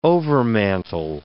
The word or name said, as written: overmantel